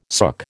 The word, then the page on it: sook: American English